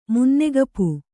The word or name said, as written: ಮುನ್ನೆಗಪು